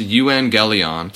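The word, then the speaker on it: euangelion (εὐαγγέλιον): It's a Greek word that's pronounced euangelion, and we can directly translate that as good news.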